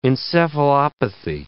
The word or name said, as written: encephalopathy